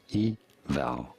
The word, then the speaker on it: Yveltal: You do want to stress on the second syllable, on the vowel syllable. Yveltal.